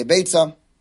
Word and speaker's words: Beitzah: So you may hear people say that it's not Beitzah but Beya, and pronouncing with an ayin as opposed to a tzadi. Okay, also interesting discussion why that's the case. But my rebbeim said Beitzah. I'm going to say Beitzah.